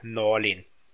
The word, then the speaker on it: Norlin: I like Pieters more than Norman. Norman. I, I don't like either. I didn't pronounce either of their names right. Thomas Pieters. Pieters. And Alexander Norlin.